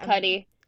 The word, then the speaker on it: Cudi: Didn't really realize that Kid Cudi was in it, or is that how you say his name? I feel like Kid Cudi.